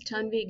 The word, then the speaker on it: Tanvi: My name is Tanvi Gandhi. It is spelled as T-A-N-V-I if the listeners want to look me up, but it's pronounced as T-U-N-V-I.